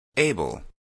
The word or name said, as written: able